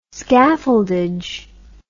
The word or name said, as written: scaffoldage